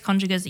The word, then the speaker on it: congeners: It's actually congeners, by the way. That's how it's pronounced.